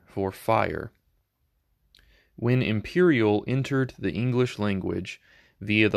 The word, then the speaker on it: empyreal: The first word that I have selected for this morning's episode is imperial. Imperial. It is spelled E-M-P-Y-R-E-A-L.